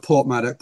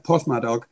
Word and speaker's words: Porthmadog: So the town of Port Madoc, Porthmadog, if you pronounce it in the local way.